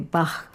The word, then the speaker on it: Bach: Esta baquiana, porque la che de Bach se pronuncia con sonido K, tiene la estructura de un aria da capo, ABA.